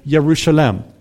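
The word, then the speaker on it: Yerushalem (ירושלים): Because in Hebrew, you pronounce the city of Jerusalem, Yerushalem.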